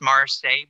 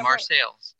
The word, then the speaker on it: Marseilles: So it should be pronounced Marseille, but it's Marseille. – Marseilles. – Yeah. So of course it's in Illinois, Marseilles.